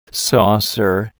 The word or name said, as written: saucer